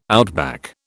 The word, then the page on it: outback: British English